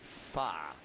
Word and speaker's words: Pfaadt: I think it's more like fa.